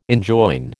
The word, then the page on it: enjoin: American English